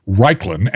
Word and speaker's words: Raichlen: And it's spelled funny. It's R-A-I-C-H-L-E-N, but it's pronounced Raichlen.